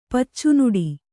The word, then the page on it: ಪಚ್ಚುನುಡಿ: Kannada